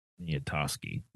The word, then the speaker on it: Poniatowski: Poniatowski, I think. – Poniatowski. – Poniatowski. – Yeah, the last two syllables are like the last seven letters. – Poniatowski. – Poniatowski. Yeah. Poniatowski. All right, I got that one wrong.